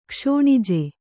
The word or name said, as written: ಕ್ಷೋಣಿಜೆ